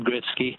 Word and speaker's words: Gretzky: Wayne Gretzky has always been pronounced Wayne Gretzky,